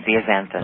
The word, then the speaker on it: zeaxanthin: It's lutein and zeaxanthin.